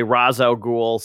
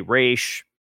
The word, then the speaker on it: Ra's al Ghul: Some people say Ra's al Ghul. Some people say Raish.